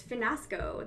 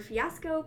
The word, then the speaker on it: fiasco: and probably the best of those was Finasco. The word fiasco pronounced Finasco by Miss Alea.